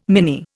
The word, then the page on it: mini: Indian